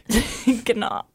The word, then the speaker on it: Gnar: I'm pretty sure the G is silent. – Gnar.